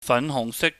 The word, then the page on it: fanhuhngsik: cantonese